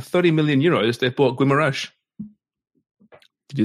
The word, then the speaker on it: Guimarães: Guimarães. I'm encouraging your... – Yeah, it's you. It's you because you pronounce it properly. – Guimarães.